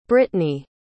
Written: britni

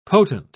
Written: potent

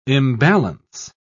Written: imbalance

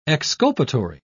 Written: exculpatory

